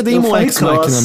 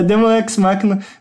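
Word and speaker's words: Daemon X Machina: Mas jogar mesmo, eu comecei e joguei 3 horas de Demon Cross Machina no PC. Como se fala? – Eu acho que é Demon X Machina mesmo. – É Demon X Machina.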